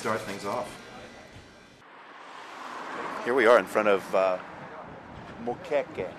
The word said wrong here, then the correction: moqueca: Well, it's moqueca, but I've seen it spelled with an O, moqueca. But moqueca. – You can do it either way, moqueca or moqueca.